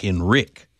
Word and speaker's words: Enrique: But Enrique actually pronounces his name more like Enrique.